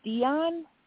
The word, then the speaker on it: Dion: My name is Dion. – Dion. Okay. Dion, like the singer.